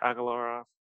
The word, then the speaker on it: Agalarov: I think that's how you pronounce it, Agalarov. – I think so. That sounds right.